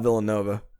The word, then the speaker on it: Villanueva: Villanueva. – I'm pretty sure it's Sal Villanova. – Villanova. That's not how you spell Nova. – I understand, but I think the pronunciation is Sal Villanova.